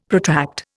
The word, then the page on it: protract: Indian